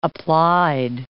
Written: applied

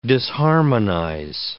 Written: disharmonize

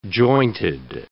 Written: jointed

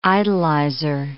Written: idolizer

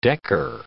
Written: decker

